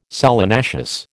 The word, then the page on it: solanaceous: American English